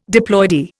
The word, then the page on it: diploidy: Indian